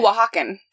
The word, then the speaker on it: Oaxaca: It's like O-A-X. I'm butchering. – Oh, Oaxacan. She's from Oaxaca. – Thank you. – Yeah. I could be saying that wrong as well, but I do know people who have gone there, and that's how they have pronounced it.